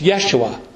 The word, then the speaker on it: Yeshua: And maybe you know the Hebrew pronunciation of that name. It's Yeshua.